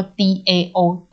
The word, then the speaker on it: DAO: 哎，那我的朋友他们还有做一个，嗯、就是还做一个那个，就是加密世加密货币世界里面的一个公司的组织，他们叫做 DAO 道，就是道理的道，嗯、我觉得发音很像哈。